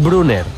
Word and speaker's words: Brunner: Paris Brunner, delantero centro alemán, juega en el Mónaco.